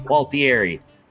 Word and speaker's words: Qualtieri: It is our special guest, Josh, probably going to hack up his last name, Qualtieri. How'd I do? – That was actually pretty good. It's Italian. You just pronounce all the vowels and you can't.